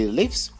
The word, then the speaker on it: lives: And number 6, he lives with his parents. He lives with his parents. That's the short E sound in the third person singular.